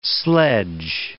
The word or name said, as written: sledge